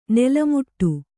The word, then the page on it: ನಾಲ್ಮಡಿ: kannada